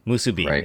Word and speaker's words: musubi: Yeah, like spam musabi, right? – Musubi is how you pronounce musubi? – It.